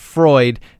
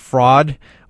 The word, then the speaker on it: Freud: Instead of Sigmund Freud, they always call him Sigmund Fraud. Sigmund Freud. Hey, Sigmund Freud, you know, instead of Fraud, which is always great.